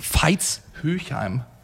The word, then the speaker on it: Veitshöchheim: Ich habe gesehen auf Instagram, du hast wieder einige Spiele über dich ergehen lassen müssen und Veitshöchheim, wenn ich es jetzt richtig ausgesprochen habe. – Veitshöchheim.